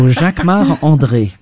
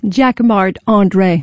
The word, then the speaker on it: Jacquemart-André: Jacquemart-André. Jacquemart, or no, you say it like André. A Frenchman. – Jacquemart, of course, because I'm French. So Jacquemart-André.